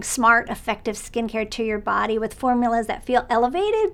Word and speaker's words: BDY: This is a brand new body care brand from the makers of Tree Hut called Body. It's spelled B-D-Y,